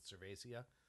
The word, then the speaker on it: cerevisiae: It's almost like I always pronounce it Cervacea. – Cervacea, okay. – Just because of the, yeah, that Cervace of Spanish.